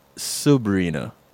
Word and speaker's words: Subrena: And for those that are listening and um, not seeing how her name is spelled, it's an S U for Subrena. That's right. Not Sabrina. – Yes. It's it's not like the teenage witch. It's that's right. It's Subrena, not Sabrina.